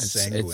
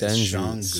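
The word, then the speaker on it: Şengün: It's not Sanguin. It's Shangun.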